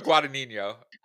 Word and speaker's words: Guadagnino: All right, so Challengers is a romantic sports drama directed by Luca, let's see if I butcher this, Guadagnino. Did I make it that Italian? It may be, but... Hey, Luca Guadagnino.